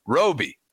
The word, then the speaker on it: Roby: I said Toby. It's late. I'm looking at it. Oh, he's setting me up. Okay. Rhymes with Toby. Hey, Toby. No, Roby.